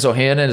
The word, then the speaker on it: Ohanian: Alexis Ohanian, is that how you say his name? – Yeah.